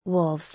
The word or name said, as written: Wolves